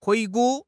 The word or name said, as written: 回顾